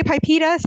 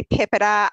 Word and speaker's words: PIPEDA: So some people say PIPEDA, some people say PIPEDA. I don't know that there's a right way.